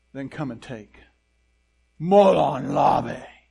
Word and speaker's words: Molon Labe: Molon Labe.